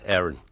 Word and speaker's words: Aaron: No, Aaron. Tu tranquil. – Com ho dius el teu nom? – Bueno, en anglès, Aaron. – Imagina't. – Però no, Aaron està bé.